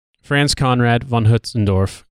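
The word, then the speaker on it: Franz Conrad von Hötzendorf: And for the record, the guy's name is actually pronounced Franz Conrad von Hutzendorf, or if you want to be really fancy, Franz Conrad von Hutzendorf.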